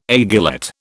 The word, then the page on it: aiguillette: British English